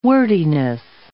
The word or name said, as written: wordiness